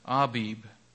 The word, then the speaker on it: Abib: I was looking at I pronounced it all a number of different ways, but if you look at the dictionary, it says it's a Abib. So I'll try to remember that and do that consistently because that's how it's supposed to be pronounced.